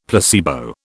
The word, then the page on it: placebo: British English